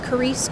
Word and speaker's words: Charis: So currently, Ignite is only available in print, in person, or you can reach me on my Instagram at Carice Crow. And I know my name is not normal, but it's spelled C-H-A-R-I-S.